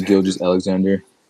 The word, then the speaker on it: Gilgeous-Alexander: Shai Gilgeous-Alexander.